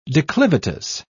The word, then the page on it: declivitous: British English